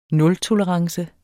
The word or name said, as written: nultolerance